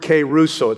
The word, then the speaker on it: kerusso: Now, the word preach there is the Greek word keruso. It's spelled K-E-R-U-S-S-O. Keruso.